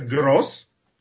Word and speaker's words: gros: Po prostu pisze się zawsze gros, G-R-O-S, a wymawia się albo gros z tym naciskiem na S tylko wtedy, kiedy się daje, podkreśla liczbę konkretną, 144, albo się wymawia gro, kiedy się używa tej liczby w sensie takim ogólnym większej liczby.